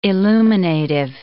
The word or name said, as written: illuminative